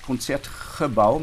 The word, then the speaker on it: Concertgebouw: Naja, also die holländische Aussprache ist Konzertgebau, mit das, da wird das G wie ein CH ausgesprochen mhm.